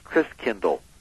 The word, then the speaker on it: Christkindl: And so the German pronunciation of Christ child was Christkindl.